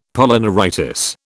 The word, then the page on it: Polyneuritis: British English